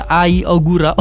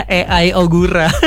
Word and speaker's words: Ai Ogura: Jadi ada pembalap rookie Trackhouse Aprilia, namanya AI Ogura. Masa mau bacanya AI Ogura? Ai Ogura ini orang Jepang ya, berarti jelas banget dari namanya.